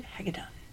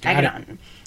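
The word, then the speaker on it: Hagadone: Did I say it right? Hagedon. – Nailed it. – Hagedon.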